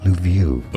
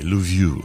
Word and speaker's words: Lviv: We need to learn how to pronounce this. Lviv. This is the Ukrainian town. Let's see how it's pronounced. – Lviv. – Okay, Lviv.